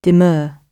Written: demur

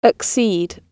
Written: accede

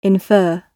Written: infer

